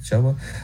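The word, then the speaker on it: Chubba: It's not even close, but Chuba Purdy. It rhymes with Bubba. Chuba.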